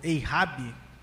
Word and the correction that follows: hijab: pronúncia está correta? Como é que é, o Pablo, isso aí? Você que é um grande conhecedor. – Hijab. Hijab, usando Hijab.